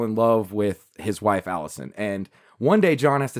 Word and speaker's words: Alisoun: It's spelled different, but it's Allison. – Uh-huh. – It's spelled like A-L-I-S-O-U-N. Allison. Allison, but I just call her Allison.